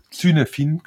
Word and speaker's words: Cynefin: Auch hier würde ich mal empfehlen, wenn irgendjemand weiterlesen will, mal zu recherchieren, zu googeln nach dem Begriff Cynefin, also schreibt mir den C-Y-N-E-F-I-N, Cynefin quasi, aber wird Cynefin ausgesprochen. Und das ist ein Framework auch,